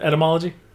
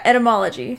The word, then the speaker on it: etymology: Entomology? – Did I say that wrong? Is it etymology? – Yeah, etymology.